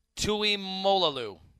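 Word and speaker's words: Tuimoloau: And with this player that Ohio State just signed yesterday – for those that don't know, JT, I don't know if I'm pronouncing this right at all, Tuimolalu. You want to go with that? – Molalu, yeah.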